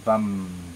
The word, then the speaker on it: Vam: And next we will do the Bija mantra, the seed mantra for the second chakra, which is Vam. – 接下来我们会做第二脉轮的种子音唱诵，它的发音是 Vam, V-A-M.